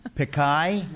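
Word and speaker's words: Pichai: Pickai. – Pickai? – Yeah.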